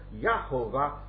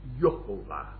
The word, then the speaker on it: Yehovah: But when you have three syllables, you must lighten the first syllable, take away the stress from the first syllable. And so Yahovah became Yehovah.